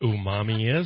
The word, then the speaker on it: umami: I don't know what umami is.